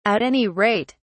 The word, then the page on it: at any rate: British English